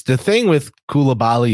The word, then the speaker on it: Koulibaly: Anyway, number seven, Bilal Koulibaly. Did I pronounce that correctly? Koulibaly. Yeah.